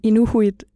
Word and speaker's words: Inuhuit: Vi kender godt ordet Inuit, men Inuhuit er den måde, de udtaler dem selv på derop.